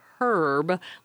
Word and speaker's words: herb: Now, should one use the H? And those who speak the Queen's English aspirate the H and say a herb, like the man's name. But Americans generally don't use that first letter, and we use an herb, E-R-B.